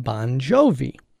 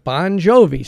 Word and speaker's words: Bon Jovi: And John Bon Jovi spells it B-O-N-J-O-V-I, but that is merely for phonetic reasons so that you can pronounce it properly. His correct spelling is the same as my spelling, B-O-N-G-I-O-V-I.